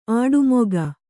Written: ಆಡುಮೊಗ

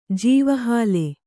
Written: ಜೀವಹಾಲೆ

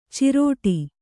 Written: ಚಿರಡಿ

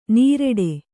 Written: ನೀರೆಡೆ

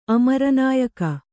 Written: ಅಮರನಾಯಕ

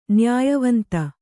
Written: ನ್ಯಾಯವಂತ

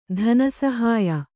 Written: ಧನಸಹಾಯ

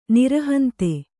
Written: ನಿರಹಂತೆ

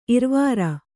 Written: ಇರವಾರ

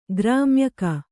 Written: ಗ್ರಾಮ್ಯಕ

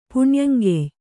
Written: ಪುಣ್ಯಂಗೆಯ್

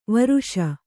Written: ವರ್ಷ

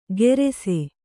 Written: ಗೆರೆಸೆ

